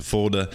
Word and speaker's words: Fulda: Am I saying that correctly? – Fulda, Germany.